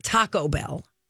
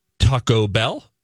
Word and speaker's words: Taco Bell: It's Taco Bell, not Taco Bell. – He says taco with a really strong O, bell with bell being higher in tone. Taco Bell?